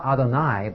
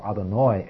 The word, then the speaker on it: Adonai: If there's more than one person, you would say Adonai, with a short A. But he's not addressing more than one person here. He's addressing one person. "If now I have found favor in thy sight." And the word he uses is not Adonai, but Adonai, with a long A, or Adonoi, as it's sometimes pointed, which doesn't mean "my Lord."